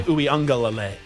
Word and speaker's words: Uiagalelei: DJ Uiagalelei.